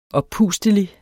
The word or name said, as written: oppustelig